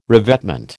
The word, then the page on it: revetment: American English